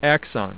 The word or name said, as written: axon